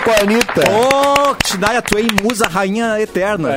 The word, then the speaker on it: Shania Twain: É, não sei como é que pronuncia, se é Shania Twain. – Ah, é, Shania, é, é, Twain.